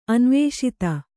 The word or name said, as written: ಅನವಸ್ಥೆ